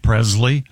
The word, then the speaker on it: Presley: Why are you mispronouncing Presley? – What did I say? – Presley. – It's a hard Z.